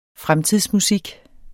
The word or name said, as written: FREMTIDSMUSIK